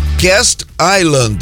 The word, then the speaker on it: Castle Island: A minha favorita chama Cast Island. Escreve assim mesmo, de castelo, né? Castle, né? Castle. Não, não. Não, não tá aqui. É Castle Island. Então se escreve C-A-S-T-L-E. De castelo, tá?